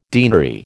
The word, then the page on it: deanery: American English